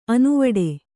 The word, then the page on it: ಅನುವಿಡಿ: anuviḍi